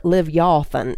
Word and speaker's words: Leviathan: He says, "Let those curse it who curse the day, who are skilled to rouse up Leviathan," or Leviathan is actually how you're supposed to pronounce it.